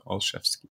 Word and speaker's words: Olszewski: It's the way I recommend folks pronounce it is to think of three words, all A-L-L, chefs as in cooks, and then ski as in downhill skiing. So Olszewski.